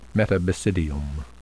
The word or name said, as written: metabasidium